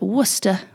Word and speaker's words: Worcester: And that was at Worcester. I know how to pronounce it because I'm English.